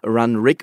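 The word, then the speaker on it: Runrig: Die heißen Run Rig und nicht Run Ring, wie ich es fälschlicherweise gesagt habe.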